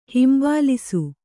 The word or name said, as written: ಹಂಬಲಿಸು